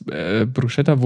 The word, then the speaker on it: Bruschetta: Bruschetta.